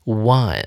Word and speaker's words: won: 放眼望去，A、 B、 C 三个选项都没有接动词，只有猪 won 哦，这个赢得胜利的过去式 won 啊，念成 O N E 的发音。